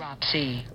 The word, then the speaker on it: necropsy: Necropsies? – Necropsy. – What was that? – Necropsy. – That's how you pronounce it.